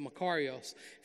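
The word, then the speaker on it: makarios (μακάριος): You know, this Greek word for blessing is makarios. There's different ways to pronounce it, but makarios. If you know anything about Greece, there's an island off of Greece that belongs to Greece called the Makarios Island.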